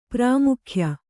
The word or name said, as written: ಪ್ರಾಮುಖ್ಯ